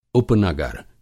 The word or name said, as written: उपनगर